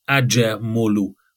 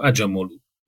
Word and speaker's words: Acemoglu: Hát igazából megnéztem nagyon sok helyen, hogy kell kimondani neki a nevét, Kámer Darren Acemoglu. Úgy kell mondani a nevét, tehát a J-vel és a Mogluban a G betű nincs benne. Tehát a kiejtés legalábbis, kiejtés szempontból. Acemoglu.